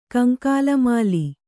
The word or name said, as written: ಕಂಕಾಲಮಾಲಿ